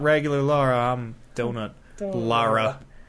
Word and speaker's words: Lara: It's L-A-R-A. – It's Lara. – Yeah, Lara. – Yeah. – It's like Laura, but Lara. It's the... – Lara as L-A-U.